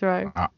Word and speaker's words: Wroe: It is Wroe. It's Wroe.